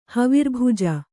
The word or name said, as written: ಹವಿರ್ಭುಜ